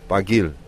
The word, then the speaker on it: Barguil: Warren Barguil. I got a pronunciation lesson. Barguil. – You do. We checked with Francois Thomaso last night.